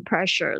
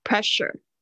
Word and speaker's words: pressure: 嗯，然后所以大家不要念成 pressure 了，是念 pressure。就是 s u r e 这个是发 sure 这边啊，特别是 pressure，当然它会有例外发日的声音。